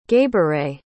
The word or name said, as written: gabouray